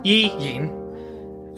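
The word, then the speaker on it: Yi Yin: And his name is Yi Ying, and I'll say it again, just because when I pronounce it, it does sound like I'm saying one name, but it's actually two. Yi Ying.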